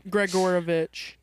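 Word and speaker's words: Gregorovitch: How do I say that? – You say it Wands. – Gregorovich.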